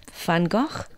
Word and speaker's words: Van Gogh: Van Gogh.